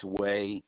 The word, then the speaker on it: Wehe: Last name is W-E-H-E, pronounced Way.